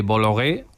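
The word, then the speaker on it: Bolloré: Más allá del cine, Antonio, la controversia sobre la influencia de Bologué, ahora sí lo digo bien, en el debate público francés es muy frecuente. – Y esto es un dato clave. Bologué, además de Canal Plus, es dueño de un conglomerado de medios de comunicación.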